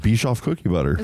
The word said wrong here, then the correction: Biscoff: Is that how you say it? – Is it Bischoff? – Biscoff.